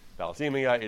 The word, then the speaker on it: thalassemia: This was with regard to a disease called phesalamia. I'm not sure I'm pronouncing that right, but it's a disease. – Thalassemia. – I'm sorry, thank you. Thalassemia, it is a disease by which that affects the blood, that the cells are not, the blood cells are improperly constructed.